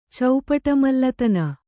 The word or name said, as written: ಚೌಪಟಮಲ್ಲತನ